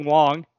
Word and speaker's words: Wang: So I think it's going to be like when we used to watch Chin Ming Wong, which I am pronouncing correctly and is W-A-N-G, mm-hmm.